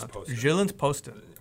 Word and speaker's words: Jyllands-Posten: Jyllands-Posten.